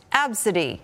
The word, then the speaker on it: ABCDE: Tracy Redford's daughter's name on her boarding pass is spelled A-B-C-D-E, but it's pronounced Absidy.